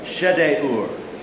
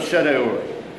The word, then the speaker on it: Shedeur: But for weird names, always pronounce the I as an it or an E, pronounce every vowel, and what you'll get is Elizur, son of Shedeur.